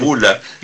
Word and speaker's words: Bula: La chica que juega al hockey creo que se escribe con G, su apellido, y mi apellido con B. Y lo que pasa es que yo no he logrado que me digan Bula, pero es Bula, y supongo que ella será Gula.